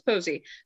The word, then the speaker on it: Posey: Yes, Alex Posey.